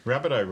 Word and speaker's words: Rabbitoh: Rabbitoh rhymes with Russell Crowe as well, so has that got something to do with...